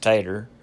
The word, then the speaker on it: potato: Other examples are potato, pronounced tater, tomato, pronounced mater, and tobacco, pronounced backer.